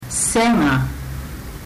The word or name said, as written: sega